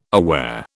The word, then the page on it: aware: British English